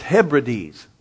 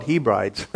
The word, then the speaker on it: Hebrides: And during that time, I mispronounced an area of Scotland, and it's Hebrides, I found out. Somebody shared, well, it's pronounced Hebrides. I call it Hebrides. Okay, so I was wrong. I admit it, I was wrong. Mispronounced as Hebrides.